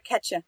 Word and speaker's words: Kacha: Okay, how would you pronounce it in your native tongue? – Kacha. – Kacha. Mm-hmm. Wait, did that sounded the same to me, or is that just? – It's not the same.